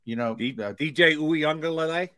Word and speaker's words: Uiagalelei: DJ Uiagalelei? – Yeah.